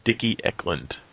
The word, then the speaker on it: Dickie Eklund: It is Dickie Eklund.